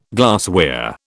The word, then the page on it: glassware: British English